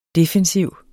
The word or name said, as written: defensiv